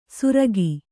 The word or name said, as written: ಸುರಕಿ